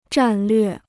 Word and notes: 战略: zhàn llüè

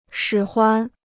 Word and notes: 使唤: shǐ huan

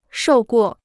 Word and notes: 受过: shòu guò

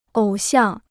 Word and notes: 偶像: ǒu xiàng

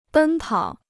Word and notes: 奔跑: bēn pǎo

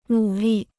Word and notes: 努力: nǔ lì

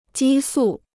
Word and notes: 激素: jī sù

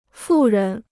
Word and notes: 富人: fù rén

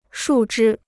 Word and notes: 树枝: shù zhī

